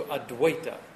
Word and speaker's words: Advaita: The V is like a W. So Advaita. Sri Advaita.